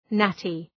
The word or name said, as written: natty